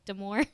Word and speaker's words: Damore: Is that how you pronounce his last name, Brooke? Damore? – Yes, I believe so.